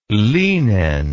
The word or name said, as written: lynen